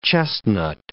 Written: chestnut

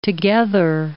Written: together

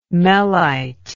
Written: mellite

